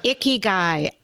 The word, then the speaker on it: Ikigai: Yes, I better pronounce it for you. – See what it's I did called, there? – Yeah. It's called Ikigai. – Ikigai.